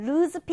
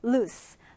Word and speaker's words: loose: 우리가 말하는 루즈 핏 티셔츠는 사실은 루즈가 아닌 거예요. 루스, 루스 핏 티셔츠 이렇게 말해야 합니다.